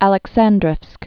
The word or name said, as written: Aleksandrovsk